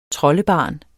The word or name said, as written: troldebarn